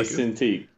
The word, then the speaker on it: Cintiq: Shout out to Lady Bar, because she said his wife brought him a, how do you pronounce it, Cintiq? Is that the throw that in there. She bought me a Cintiq.